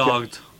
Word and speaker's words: Delort: Delort.